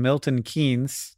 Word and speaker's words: Milton Keynes: Yeah, so, it's weird that the franchise currently applying its trade in Milton Keynes.